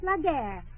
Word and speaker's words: la guerre: Say la guerre. – All right, la guerre. Did I say it right? – Oui.